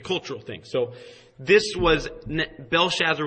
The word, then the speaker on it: Belshazzar: It's Belshazzar.